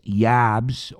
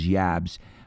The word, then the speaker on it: Jabs: And that was the first album with a new guitar player replacing Uli John Roth was Matthias Jabs, or Jabs. I think the German pronunciation is Jabs, but Matthias, for sure, we can all agree on that pronunciation of his first name.